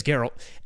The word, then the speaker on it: Geralt: And I probably said the name wrong. – Geralt. – It's Geralt.